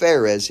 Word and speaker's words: Perez: It's pronounced Perez here in verse 3.